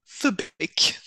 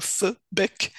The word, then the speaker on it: fubik: But the one that will probably be the easiest for you to pronounce is fubik. So that's a kind of, think of the pronunciation like the first part of full and the first part of bickering, so fubik.